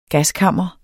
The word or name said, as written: gaskammer